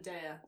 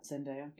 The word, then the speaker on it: Zendaya: Zendaya? I never know how to say it. – Zendaya. – Zendaya? – Yeah.